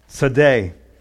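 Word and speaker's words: Tsade: Our next letter here is Sadi. It's pronounced Sade.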